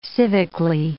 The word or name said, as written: civically